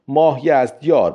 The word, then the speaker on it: ماهیزدیار: در میان آنها بلازری نام او را مایزدیار نوشته است، لذا میتوان پذیرفت که تلفظ درست نام او ماهیزدیار بوده است.